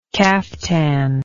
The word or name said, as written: caftaned